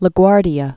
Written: La Guardia

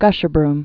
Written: Gasherbrum

